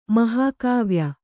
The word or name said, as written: ಮಹಾಕಾವ್ಯ